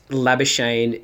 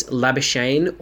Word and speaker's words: Labuschagne: So basically, he's asked because Labuschagne is... there's a lot of debate around the world, always has been, probably always will be, about how it's pronounced. So the two pronunciations is Labuschagne or Labuschagne, pretty much.